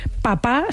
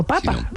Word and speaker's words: papa: Porque la acentuación histórica, o sea, la manera histórica que en español hemos tenido de llamar a nuestros padres ha sido papa, mi papa.